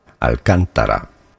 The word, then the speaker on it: Alcantara: Word pronunciation, a word from Spanish. Originally, Spanish pronunciation, Alcantara.